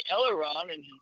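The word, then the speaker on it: aileron: But as far as his long-term memory was concerned, I could say Eleron or misquote it, and he said, no, it's pronounced Eleron, you know?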